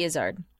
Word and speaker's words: Izzard: Eddie Izzard.